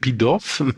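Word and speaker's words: Pitof: Pitoff, ja, muss französisch ausgesprochen werden, Pitoff oder so, ist ja Franzose.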